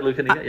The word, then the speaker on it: Lucanica: I was going to give you a shout out on your Greek heritage because I know the Lucanica. – Yeah. – Did I say that right? Correctly? – Yeah, exactly. Lucanica. Yeah.